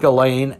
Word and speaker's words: Ghislaine: So you take Ghislaine and you take Jeffrey Epstein, and Ghislaine falls more into the pedophile role than actually Epstein does himself.